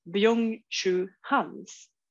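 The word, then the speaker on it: Byung-Chul Han: Eh, och att man liksom vill fjärma sig från det där och att det på något sätt, jag tänker nu med reservation för uttal, Byung Chu Hans Perfekt, jag kan bekräfta att det är helt korrekt.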